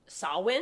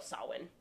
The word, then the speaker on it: Samhain: So the holiday roots stem from Samhain.